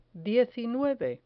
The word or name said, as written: diecinueve